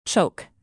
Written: choke

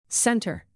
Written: center

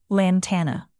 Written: lantana

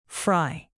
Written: fry

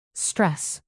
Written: stress

